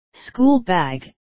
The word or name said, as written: schoolbag